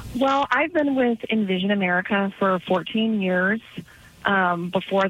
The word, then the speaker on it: InVision: On Vision, is that correct pronunciation? – Oh, we usually say InVision. – InVision, On Vision, InVision, okay, E-N, yeah.